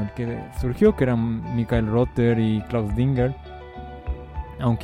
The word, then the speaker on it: Michael Rother: No, Michael es un nombre, la pronunciación anglosajona es Michael Rother, que tocó con Kraftwerk, con Can, Harmonia, Cluster, Noi y también con Iplank.